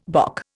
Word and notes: bock: Indian

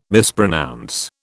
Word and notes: mispronounce: British English

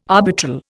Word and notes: arbitral: Indian

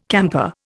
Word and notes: camper: Indian

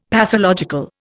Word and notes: pathological: Indian

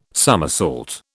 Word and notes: somersault: British English

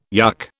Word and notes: yuk: British English

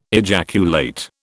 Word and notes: ejaculate: British English

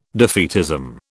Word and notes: defeatism: British English

